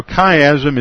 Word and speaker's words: chiasm: Now, a chiasm is a literary device that was used to order topics. And the Greek letter chi used to be pronounced chi. That's why it's pronounced chiasm.